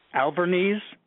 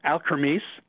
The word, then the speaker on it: Alkermes: More recently, Jeff devoted his services to companies like Alkermes. I'm pronouncing it incorrectly. Let me say that again. It's Alkermes and Astellas,